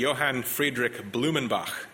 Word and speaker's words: Johann Friedrich Blumenbach: The first is a German scientist whose name was Johann Friedrich Blumenbach.